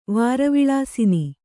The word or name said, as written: ವಾರವಿಲಾಸಿನಿ